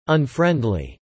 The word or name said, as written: unfriendly